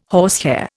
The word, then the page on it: horsehair: Indian